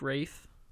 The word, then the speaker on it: Ralph: Although he likes to, he says that his name is Rafe. But it's spelled like Ralph.